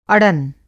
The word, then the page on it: अड़न: Hindi